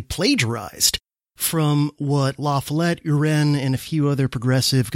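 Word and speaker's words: U'Ren: it looks like Uren, it's U, apostrophe R-E-N, Uren maybe,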